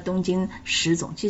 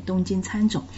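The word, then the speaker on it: 东京喰种: 这动画片的名字呢，东京餐种呢，确实这个餐字啊比较少见，口字旁加个食物的食，很容易就念成食种哦。而且这里面的餐种这种种类的动物呢，确实它是吃人的，所以它确实个食人，所以很容易就念成了东京食种。其实这里面应该是念东京餐种。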